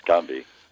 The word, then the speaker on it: Gombe: Is it Gombe? Is that how you say it? – Yes, Gombe. – Gombe. – Gombe or Gombe.